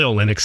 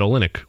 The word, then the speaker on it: Olynyk: Olynyk. – So you're saying Olynyk. You're holding the O a little longer than I am.